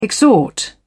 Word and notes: exhort: British English

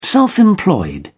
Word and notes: self-employed: British English

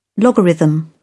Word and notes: logarithm: British English

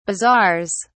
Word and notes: bazaars: British English

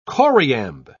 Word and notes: choriamb: British English

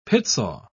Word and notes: pitsaw: British English

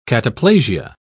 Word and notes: cataplasia: British English

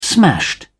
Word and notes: smashed: British English

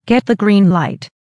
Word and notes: get the green light: American English

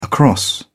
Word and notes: across: British English

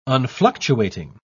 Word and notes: unfluctuating: British English

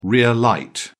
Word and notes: rear light: British English

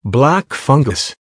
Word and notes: black fungus: American English

